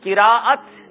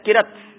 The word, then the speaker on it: قراءت: قراءت، قراءت لوگ کہتے ہیں قرت کر رہا تھا، قرت لفظ درست نہیں ہے، لفظ ہے قرآن پاک کے لیے جو استعمال ہوتا ہے وہ قراءت ہے، اور قراءت صحیح تلفظ ہے.